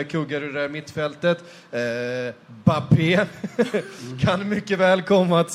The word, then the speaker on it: Mbappé: Med sitt stumma M i början av allt. – Alltså, är det så? Kan vi få ett riktigt uttal på? – Kylian Mbappé. – Mbappé. – Ja, exakt så.